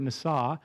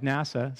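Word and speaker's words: nasa (נשא): It's the Hebrew word nasa, spelled like NASA, so you'll remember that, nasa.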